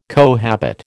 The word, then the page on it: cohabit: American English